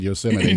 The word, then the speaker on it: Yosemite: I said Yosemite. – Yeah, you did. – They said they hear it all the time, especially from East Coasters for some reason. I always shocked that people don't know how to say Yosemite and that foreign visitors know how to say it correctly more than American visitors.